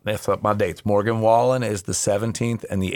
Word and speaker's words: Wallen: In July, Morgan Wallen, did I say his name right? I always say Whalen, yep. Wallen.